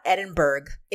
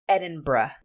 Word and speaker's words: Edinburgh: That's how you pronounce that. It's not Edinburgh. It's Edinburgh, which I have to deliberately concentrate and make sure I say it right.